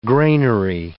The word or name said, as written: granary